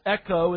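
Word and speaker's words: echo (ἔχει): The Greek verb has, echo in the original language, the way it's pronounced, is in the present tense.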